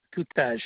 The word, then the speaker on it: Tutaj: Sure, Henry Tutaj. It's spelled T-U-T-A-J.